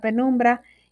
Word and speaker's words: penumbra: Esta palabra penumbra es una palabra llana, no se acentúa en la penúltima sílaba debido a que termina en vocal. Bueno, espero que les haya gustado esta palabra penumbra y que puedan utilizarla cuando vean un eclipse y usarla y reconocerla cuando la vean por ahí.